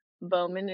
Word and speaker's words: Bowman: Bowman is spelled like a bow, B-O-W, and man, M-A-N. And Ally is spelled A-L-I. So A-L-I, B-O-W, M-A-N.